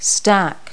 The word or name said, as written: stack